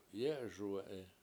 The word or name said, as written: yE7eSuwE7i